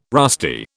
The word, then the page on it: rusty: British English